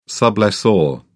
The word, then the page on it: sublessor: British English